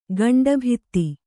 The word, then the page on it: ಗಂಡುಭತ್ತ: gaṇḍu bhatta; Kannada